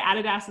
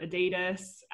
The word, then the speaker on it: Adidas: I would have like we say Adidas as well, not Adidas. Just like little pronunciation of things is quite different.